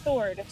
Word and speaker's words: sword: Now, it doesn't necessarily begin with a silent W. It just has a silent W in it. A long-bladed weapon. – Sword.